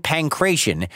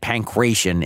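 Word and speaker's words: pankration: And yeah, I just said pancration in the last sentence, but in our previous Olympics episode, I pronounced it as pancration. As it turns out, both are right. I'm just mixing it up a little bit. You decide which one you like better.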